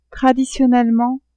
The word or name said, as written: traditionnellement